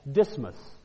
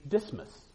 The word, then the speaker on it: Dysmas: And it's his name is spelled D-Y-S-M-A-S, Dismas.